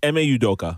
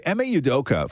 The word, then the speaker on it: Ime Udoka: It's Ime Udoka. – Okay, Ime Udoka.